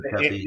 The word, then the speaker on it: Tepe: I think it is said Tepe.